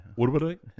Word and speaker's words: Uruguay: Uruguay? It sounds like you got water in your mouth. – Uruguay? – Is that how you say it? – That's how you say it.